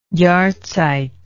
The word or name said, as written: Jahrzeit